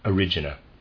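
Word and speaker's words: Erigena: It is spelled E-R-I-G-E-N-A. E-R-I-G-E-N-A. And I am pronouncing it one of the ways I read about, Origena.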